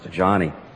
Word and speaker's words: Joni: Johnny started, by the way, Johnny is spelled J-O-N-I. Looks like Joni, but it's pronounced Johnny.